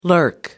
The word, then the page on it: lurk: American English